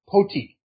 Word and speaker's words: Poti: pulled into the Georgian port of Poti. And there are a variety of ways to pronounce that. That's the one I'm going to choose to run with.